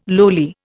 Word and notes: lowly: Indian

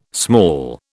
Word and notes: small: British English

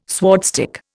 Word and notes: swordstick: Indian

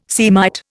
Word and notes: Semite: Indian

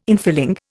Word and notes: infilling: Indian

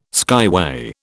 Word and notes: skyway: British English

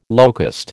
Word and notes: locust: American English

